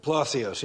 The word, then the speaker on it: Palacios: I think I got a picture in a minute, but there was Andrew, how do you say, is it Palosis or something like that? Palacios, yeah, I was close.